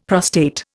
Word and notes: prostate: Indian

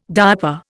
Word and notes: diaper: Indian

